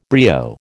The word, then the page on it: brio: American English